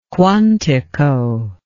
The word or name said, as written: Quantico